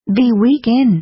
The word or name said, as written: be weak in